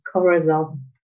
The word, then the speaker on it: Corazon: Yes, that is correct. It's Corazon. Yeah, it's like chromosome, but for that, it's the Corazon.